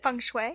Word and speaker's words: feng shui: For example, with, Feng I think it's feng shui. – Feng shui. Yes, I was. Mm-hmm. I thought I had the pronunciation. – No, you're okay. Feng shui.